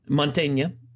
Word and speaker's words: Montaigne: But if you're from Montaigne, if you're from Montagne, which I probably mispronounced horribly. – Montaigne.